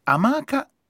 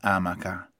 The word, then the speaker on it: amaca: Secondo questo libro, la pronuncia corretta delle seguenti parole sarebbe amaca e non amaca e infido e non infido. Entrambe con l'accento sulla seconda vocale e non sulla prima.